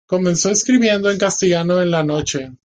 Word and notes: castellano: spanisch

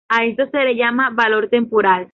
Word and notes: temporal: ispanskii

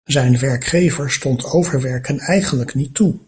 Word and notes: overwerken: Dutch

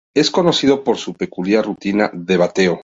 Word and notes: peculiar: ispanskii

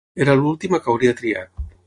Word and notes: triat: Catalan

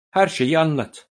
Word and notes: anlat: Turkish

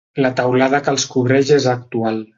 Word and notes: cobreix: Catalan

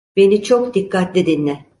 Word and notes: dikkatli: turkce

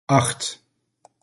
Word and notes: Acht: Dutch

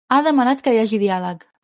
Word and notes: hagi: catalan

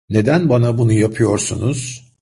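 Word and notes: bunu: Turkish

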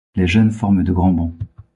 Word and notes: forment: French

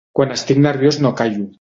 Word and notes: callo: català

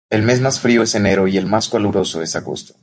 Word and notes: caluroso: Spanish

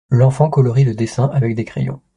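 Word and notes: crayons: french

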